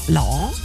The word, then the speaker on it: Lau: Der Joe in der Oststeiermark ist es der Lau. Er schreibt nasaler ausgesprochen als bei lauwarm. Also Lau, Lau, so, Lau, so, irgendwie.